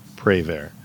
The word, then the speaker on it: Prévert: This was adapted by the Raincoats from a poem by Jacques Prévert. I don't know if I even said that right. – If it's French, the T is probably silent. – Okay. – That's all I remember Prévert. From French class.